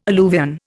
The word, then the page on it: alluvion: Indian